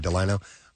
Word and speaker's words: Delino: Delino is how you say that? Okay, Delino.